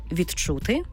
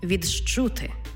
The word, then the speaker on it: відчути: «Д» і «чи». Коли вони стоять поруч, утворюється звук «дж», «чи». Тобто пишемо «відчути», вимовляємо «відчути». «Д» і «джи» утворюється «джи».